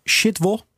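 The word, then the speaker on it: Szydło: Ja, ik zag een reactie van eh, Beata Shitwo Dan spreek je het echt goed uit. De oud-premier van eh, Polen. Maar je schrijft uh, eh, S-Z-Y-D... L met zo'n dingetje erdoorheen. O, en, maar dat is al shitwo.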